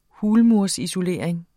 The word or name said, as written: hulmursisolering